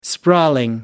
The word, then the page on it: sprawling: British English